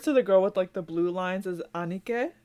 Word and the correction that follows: Aniki: or Aniki. They're Aniki. Yeah. I said it wrong.